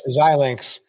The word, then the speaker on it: Xilinx: X-I-L-I-N-X. – Xilinx.